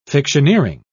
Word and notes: fictioneering: British English

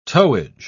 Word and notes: towage: British English

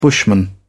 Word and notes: bushman: British English

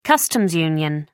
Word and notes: customs union: American English

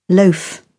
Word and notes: loaf: British English

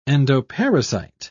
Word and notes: endoparasite: British English

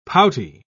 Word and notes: pouty: British English

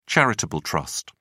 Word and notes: charitable trust: British English

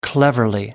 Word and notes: cleverly: American English

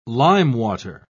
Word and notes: limewater: British English

